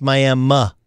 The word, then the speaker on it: Miami: And there's a town right on the northeastern tip, just the tip of the state, that right before Joplin, spelled Miami, pronounced Miami, Oklahoma.